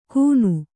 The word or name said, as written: ಕೂನು